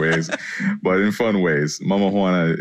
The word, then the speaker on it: Mama Juana: Is it pronounced Mama Juana or Mama Juana? – Mama Juana.